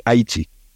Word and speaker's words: Haiti: Yes, and also a little disclaimer here, I have not been in Haiti, so that's how we pronounce the name of the country. It's the indigenous pronunciation, actually, Haiti.